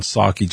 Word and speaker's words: Psaki: Remember, the P is silent in Psaki, just like the P in President Biden.